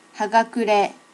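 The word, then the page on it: hagakure: native Japanese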